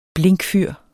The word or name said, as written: blinkfyr